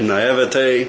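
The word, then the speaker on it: naïveté: My naivety. How does that, how do you pronounce that word? – Naivete.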